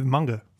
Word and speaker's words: manga: The more you go deeper into it, It's the coolest manga. – Manga? – Ever. Manga. – Oh, it was manga. – That's the traditional pronunciation is manga.